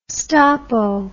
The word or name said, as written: stoppo